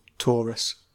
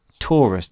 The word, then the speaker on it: Taurus: Taurus? What is it? – Taurus. – Taurus.